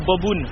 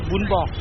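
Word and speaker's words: bún bò: Les Français disent Bobun, mais il faut bien prononcer Bun Bo.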